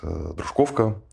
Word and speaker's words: Дружковка: Меня поправили, что я с неправильным ударением произносил название этого города. Значит, Дружковка.